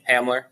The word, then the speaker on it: Hamler: He had like more as many catches for like more yards than Hemler did. Am I saying his name right? – Hamler.